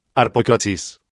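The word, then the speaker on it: Harpócrates: Los místicos de los misterios de Eleusis pronunciaban aquel nombre en la siguiente forma, Arpocrates.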